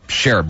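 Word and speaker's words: Scherff: He likes people to call him Sheriff. Brandon Sheriff. That's how he likes people to pronounce his last name.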